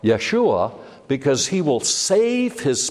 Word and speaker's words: Yeshua: So it's important we keep this in mind, because Jesus' name, which would be pronounced Yeshua or Yehoshua, is comprised of two Hebrew words.